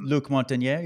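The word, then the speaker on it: Luc Montagnier: Oh, Luke Montagnier, you're talking about? – There we go. – I think that's how you say it.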